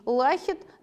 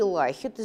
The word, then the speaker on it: Лахет: Лисимах, он нам говорит, вы, Никий Лахет, извините, я буду так ударение ставить, сейчас наблюдали за человеком, сражавшимся в тяжелом вооружении.